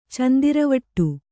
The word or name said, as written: ಚಂದಿರವಟ್ಟು